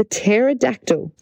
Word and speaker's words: pterodactyl: It's got a silent P. Pterodactyl is spelled P-T-E-R-O-D-A-C-T-Y-L. Pterodactyl. There you go. The P is silent.